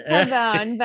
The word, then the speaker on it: Carbone: Because the only reason a lot of us know is it Carbone or Carboni? – It is Carbone, but I think the traditional Italian pronunciation.